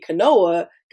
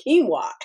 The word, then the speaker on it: quinoa: And I kept telling Brian, I got to get quinoa because I got to try this protein. And he said, babe, I love you dearly, but it's pronounced quinoa.